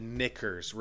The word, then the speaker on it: knickers: I said knickers. Relax. – K of what we reviewed. K-N-I-C-K-E-R. – Silent K.